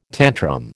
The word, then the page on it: tantrum: American English